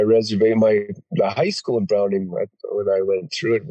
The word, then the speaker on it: Browning: Yeah, Browning. You got to say it like that.